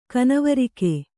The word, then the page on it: ಕನವರಿಕೆ: kanavarike